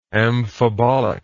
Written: amphibolic

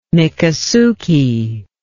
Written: mikasuki

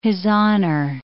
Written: hizzoner

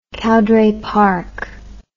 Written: Cowdray Park

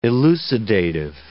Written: elucidative

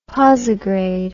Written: posigrade

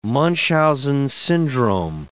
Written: Munchausen syndrome

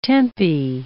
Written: tempi

